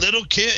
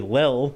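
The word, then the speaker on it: Lil' Kim: Little Kim? Isn't it Little Kim? – I don't think that's how you pronounce that. It's probably Lil, not Little, but he's old.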